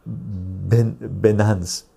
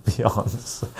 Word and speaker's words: Binance: Ding Baba Chicho, I know you meant to say Binance, but you said Beyonce.